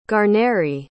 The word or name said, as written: guarneri